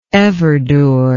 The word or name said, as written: everdur